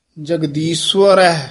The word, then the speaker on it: जगदीश्वर: जगदीश्वर स्वर है इहदा उच्चारण सस्से औंकड़ ऐड़ा रारा